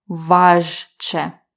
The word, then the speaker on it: важче: Важко, важче. So in pronunciation, we actually don't hear the zh because it sounds like sh to us, but in spelling, we have to spell it with zh, ch.